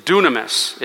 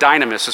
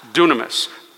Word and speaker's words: dunamis: G1411, the word is dunamis. It looks like dynamis. It's where we get the word dynamite from. But it's actually pronounced dunamis, power.